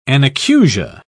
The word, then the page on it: anacusia: British English